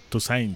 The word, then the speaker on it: Toussaint: Toussaint. – No sé cómo se pronuncia, Toussaint.